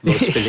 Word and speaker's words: Los Feliz: People will correct you if you say Los Feliz. – Yeah. Although that's pronunciation correct. – For Spanish. Los Feliz.